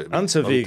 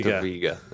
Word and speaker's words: Unterweger: Jack Unterweger. – Was he Austrian? – Yeah. – I would imagine that W is a V then. So Unterweger. – Unterweger, yeah.